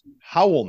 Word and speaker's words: Raúl: Honorable mention, Raúl Neto, actually pronounced Howell Neto.